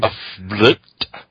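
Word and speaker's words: Fblthp: How do you pronounce that thing's name? – A flipped. – Yeah, had always hated crowds. On the off chance for people that don't know, a flipped is spelled FBLTHTP.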